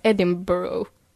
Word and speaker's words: Edinburgh: Och speciellt, paradoxalt nog så kan jag inte uttala det. Vissa säger man ska säga Edinburgh.